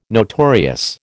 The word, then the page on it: notorious: American English